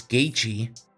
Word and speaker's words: Gaethje: If you look at Justin Gaethje's name, if you just look at it, how the fuck do you say that, right? Like, you wouldn't think it's Gaethje, but that's how you say it.